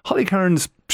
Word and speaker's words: Holly Cairns: Holly Cairns, she has, did I say her name right? – Yes. Yep, yeah.